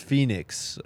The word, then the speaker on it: Phenix: People are saying it's pronounced Phoenix. All right, sorry, it's spelled Phenix though. It's P H E N I X.